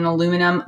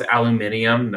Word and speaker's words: aluminium: Craft beer in an aluminum bottle, kill. Correct. Gross. Beer is gross. Gross, gross, gross. – Correct. – Agreed. – First of all, it's aluminium, not aluminum.